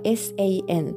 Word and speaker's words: 三: これはあの数字の時にね、以前あの練習したので、えー、あまり問題ないかなと思うんですが、一応解説しておきますと、 35、関数字の 3 は発音記号が SAN 第一声高く平たく伸ばす音